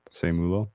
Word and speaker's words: Seumalo: So I like the move to bring in the guard Isaac Seumalo. I think I'm saying that right. – Seumalo.